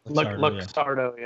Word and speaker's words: Luxardo: Looks like you're rocking Luxardo, or is? – Yeah, that's very, very good. – Is that how you say it? – Luxardo.